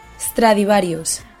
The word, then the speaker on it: Stradivarius: Inditex es eh, un grupo que eh, tiene un montón de marcas españolas como Massimo Dutti, Bershka, Pull&Bear, Stradivarius, Oyshoy, como había dicho Lucía antes, Zara, y pertenece a Amancio Ortega, el hombre más rico de España. – 对，他是西班牙最有钱的人。刚才你说到这些牌子有 Bershka、 Pull&Bear，还有那个大音符的那个标志，那个那个是怎么读的 Stradivarius.